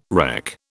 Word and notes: wreck: British English

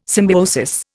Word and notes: symbiosis: Indian

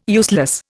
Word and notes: useless: Indian

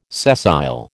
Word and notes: sessile: American English

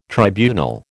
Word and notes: tribunal: American English

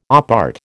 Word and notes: op art: American English

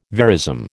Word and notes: verism: American English

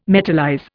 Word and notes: metallize: Indian

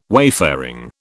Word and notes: wayfaring: British English

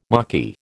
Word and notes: Maquis: American English

